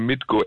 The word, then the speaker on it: Mitko: Und irgendwann klappt es auch, wenn das jetzt meinen Namen richtig aussprechen könnte bei Fritz. Ich heiße Mitko, M-I-T-K-O.